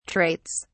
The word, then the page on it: traits: British English